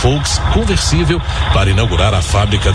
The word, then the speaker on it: Volks: As qualidades do Fusca, uma adaptação popular de Volks, que se pronuncia em alemão Volks, começaram a se espalhar pelo país.